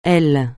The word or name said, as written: aile